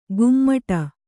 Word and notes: ಗುಮ್ಮಟ: gummaṭa

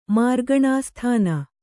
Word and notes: ಮಾರ್ಗಣಾಸ್ಥಾನ: Kannada

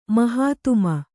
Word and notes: ಮಹಾತ್ಮ: mahātma

